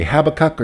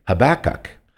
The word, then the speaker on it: Habakkuk: But there's two ways you can pronounce Habakkuk. You can say Habakkuk or you can say Habakkuk. Because one is more of a, has a, the other one, the second one has a Jewish, way they say it in the Jewish language, in Hebrew.